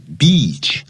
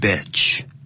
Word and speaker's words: beach: 当你站在海边的时候 ,beach, 这个长音千万要记住不要念成 beach. I went to the beach yesterday. 我昨天到母狗那边去了。那就变成母狗了 ,B-I-T-C-H, 那个叫 bitch, 所以这个叫 beach, 是 E 的长音要特别念出来。